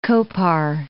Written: Kopar

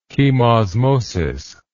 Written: chemosmotic

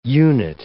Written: unit

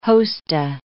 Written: hosta

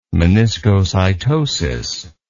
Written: meniscocytosis

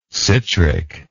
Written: citric

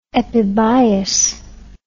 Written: epibiose

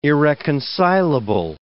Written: irreconcilable

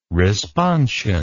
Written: responsion

